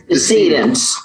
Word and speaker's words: decedent: The first is first clinical grade porcine kidney xenotransplant using a human decedent. Is that how you say it? Descendant? – I think it's decedent. – Decedent.